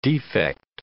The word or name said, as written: defect